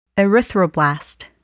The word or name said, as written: erythroblast